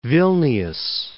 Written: Vilnius

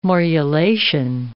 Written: morulation

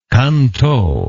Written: Can Tho

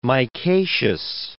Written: micaceous

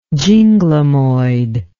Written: ginglymoid

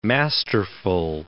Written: masterful

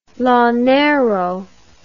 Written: llanero

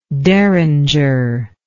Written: deringer

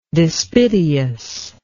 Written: despiteously

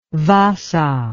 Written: vaasa

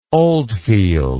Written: Oldfield

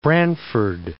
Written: Branford

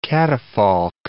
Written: catafalque

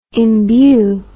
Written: embue

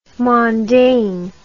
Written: mondaine

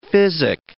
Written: physic

